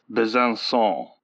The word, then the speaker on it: Besançon: Apparently, in French, a C with a cedilla is pronounced like an English S. So it's Besançon.